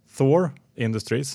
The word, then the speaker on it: Thor Industries: Karl och Henrik hörde av sig dagen efter att vi spelat in programmet och hälsade att Thor Industries, som man tydligen ska uttala det, eh, som vi pratade om här i intervjun, de la ett bud på Jayco dagen efter den här intervjun alltså.